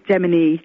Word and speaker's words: Gemini: I had a great one recently where somebody in Starbucks actually had spelt my name Gumini, G-U-M-I-N-I. I've had Domini, Jeremy, Jemima, Jemina, you name it. But it is pronounced Gemini, as though it has two E's on the end, even though it doesn't.